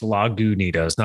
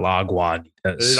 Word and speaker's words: Lagunitas: As I remember, it's pronounced Lagunitas, not Lagwanitas.